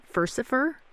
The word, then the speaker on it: Furcifer: I hope I'm saying it right, Furcifer? – Yeah, that's right.